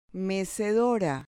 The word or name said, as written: mecedora